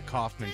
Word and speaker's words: Kaufman: F-A-R-R-A-R. I am at the Kaufman Show on Twitter.